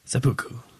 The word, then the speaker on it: seppuku: Seppuku. – Yeah, seppuku. Seppuku, which is, if the listeners... – Japanese ritual suicide. – That's what I'm talking about. For the listeners, if you don't know, seppuku is obviously the ancient Japanese ritual suicide made popular by samurais, I believe.